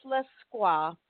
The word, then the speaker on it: Lescroart: it is pronounced Lesquois, but it is spelled Lescroart.